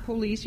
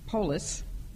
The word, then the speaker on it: polis: And police, it's not police, you know, the siren, but it sounds like the word police in Greek. It's actually, you would say polis.